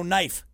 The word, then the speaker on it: knife: But then you also have, you know, knife, knife. There's a silent K in there for some godforsaken reason.